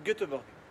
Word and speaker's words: Göteborg: I'm going to Göteborg. – Göteborg, you mean, maybe? – Ah, that's the way you say. That's the way we this, pronounce okay. it in Swedish.